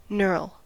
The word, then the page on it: knurl: American English